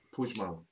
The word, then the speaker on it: Puigmal: And how do you pronounce his name again? Back, yeah. Arnau P- P- Puigmal. Puigmal. Puigmal.